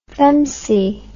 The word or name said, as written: femcee